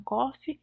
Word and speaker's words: Coffey: Que ele fala John Coffey, é igual café, mas não se escreve igual não.